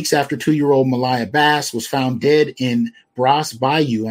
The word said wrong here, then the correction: Brays Bayou: I may have mispronounced that. Brays Bayou.